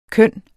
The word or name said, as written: køn